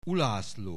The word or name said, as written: Ulászló